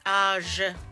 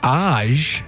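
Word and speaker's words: Aje: Oh, I don't ever know how to say it. – Age. No, if Kate's got it. – Age? – Yeah, A-G-E. A-J-E. – It's very confusing.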